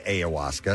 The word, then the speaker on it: ayahuasca: I'd pulled up my pronunciation for it, and it said ayahuasca.